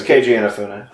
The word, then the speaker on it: Keiji Inafune: I probably mispronounced his name. – It's Keiji Inafune. – Oh, so I got it right.